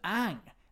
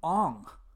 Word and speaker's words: Aang: His name's Aang. They call him Ong. Ong in the movie.